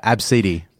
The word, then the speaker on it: Abcde: It's spelled A-B-C-D-E. – Oh, yeah. Absidy. – Yeah, Absidy. Absidy.